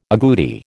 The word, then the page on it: aguti: American English